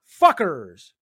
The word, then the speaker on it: Phuqors: Upcoming events, Freelance Wrestling presents, and get this, it's Trev Tember Fuckers. But it's spelled P-H-U-Q-O-R-S.